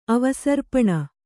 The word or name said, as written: ಅವಸರ್ಪಣ